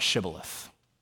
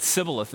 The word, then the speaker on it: shibboleth: They couldn't pronounce it right. It wasn't part of their dialect. And so whenever they said sibboleth instead, they knew that they were an Ephraimite, and they put them to death.